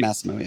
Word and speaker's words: Masimo: Masimo, yeah.